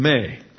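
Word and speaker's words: may (μή): And so the verse begins with a negative conjunction of prohibition, may. That's M-E, long E, has an A, long A sound in, as we pronounce it in English.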